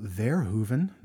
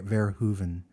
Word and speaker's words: Verhoeven: So this movie was directed by Mr. Paul Verhoeven. That's how you pronounce it. Verhoeven, Shasta.